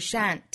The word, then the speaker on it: shan't: 还有另外一个词发音很像是 shant shant shant 相当于口语之中的 shall 和 not 的缩约，读成 shant shant。